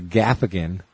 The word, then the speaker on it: Gaffigan: So during the Gaffigan, it made me laugh again. – Nice. – Did you ever know that Gaffigan rhymes with Laugh-again?